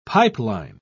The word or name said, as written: pipeline